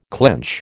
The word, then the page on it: clench: American English